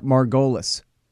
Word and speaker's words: Margolies: Spell it like Margolis. I called him Eric Margolis for a long time because I never asked him how to say his name.